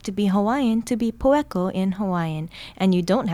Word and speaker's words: poeko: Poeko, spelled P-O-E-K-O, means fluent.